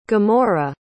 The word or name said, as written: gomorrah